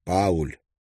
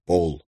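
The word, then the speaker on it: Paul: Пациент обратил его внимание на то, что его имя следует произносить не как Пауль, а как Пол. И не по-норвежски «Поль», а с твердым английским «Л», хотя Столи не смог услышать разницу.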